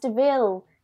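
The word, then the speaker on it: de Vil: and then Jasper's like it's pronounced Deville, and then the camera turns to Cruella, and she goes Deville.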